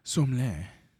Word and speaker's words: sommelier: Sommelier. – I've always heard it sommelier. – Sommelier. It just sounds like the country.